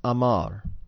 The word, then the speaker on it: Amar: The word Omer, spelled Ayin Mem Resh, is related to the word Aleph Mem Resh, pronounced Amar.